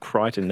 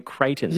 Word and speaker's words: Crichton: Most people would say, like, in terms of Crichton, they'd say Creighton.